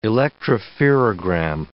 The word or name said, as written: electropherogram